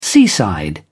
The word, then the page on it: seaside: British English